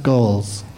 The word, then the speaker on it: Goelz: I think it's pronounced Goals. Goals. – Is it pronounced Goals? – Yes.